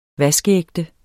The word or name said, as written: vaskeægte